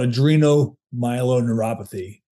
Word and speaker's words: adrenomyeloneuropathy: It's called adrenomyeloneuropathy.